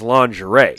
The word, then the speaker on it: lingerie: If you just know the word, it's lingerie. – Yes, thank you. – So was she reading the word lingerie? Because, like, phonetically, yeah, it does look like lingerie.